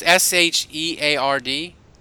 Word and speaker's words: Sheard: But I think if you told me, look, they can pair a veteran pass rusher with the draft pick, Jabal Sherrod from the Patriots. – Sheed. – It's S H E A R D. Is that how you say it? – Yeah. – So I Sheard.